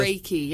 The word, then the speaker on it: Reiki: You said you can send, help me pronounce it right, it's Reiki, is it? – Reiki, yeah.